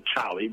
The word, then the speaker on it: Cholly: And the Cholly, which is spelled C-H-O-L-L-Y, is a name my father gave me as a baby.